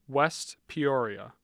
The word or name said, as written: West Peoria